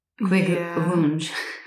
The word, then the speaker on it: Kvikklunsj: Some people said it sounds like quick lunch, but it's spelled K-V-I-K-K-L-U-N-S-J. Kvigglunch. – Yeah. Yeah, I don't know. Like kvigglunch.